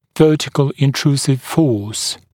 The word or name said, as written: vertical intrusive force